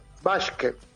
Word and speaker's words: Waschke: Que es Mark Waschke. Vos me pronunciarás mejor que yo. Ay, perdón. – Waschke. – Ok, Waschke. Sí.